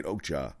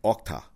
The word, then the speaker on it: Okja: I forget that you Americans say it Okja. In the movie, the Asians say Okta. But the Americans say Okja.